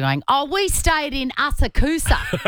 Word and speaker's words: Asakusa: There's a suburb in Tokyo called Asakusa, and it's spelled Asakusa. So phonetically, Asakusa. But yeah, you don't pronounce the U, and because I'm basically a local, I know that it's called Asakusa.